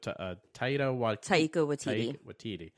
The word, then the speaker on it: Taika Waititi: It's worth noting also, I believe, is I can never pronounce his name correctly, but Taika Waititi.